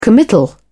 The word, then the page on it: committal: British English